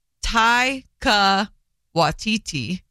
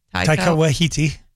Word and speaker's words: Taika Waititi: And Rita Ora and her husband, Takiti. – I love him. Sorry. Taika Waititi. – Mm-hmm. – Taika Waititi.